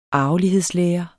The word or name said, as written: arvelighedslære